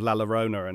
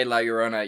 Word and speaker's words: La Llorona: Well, you just, from now on, when we say La Llorona, you have to really roll your R's. Let's have that really like, La Llorona.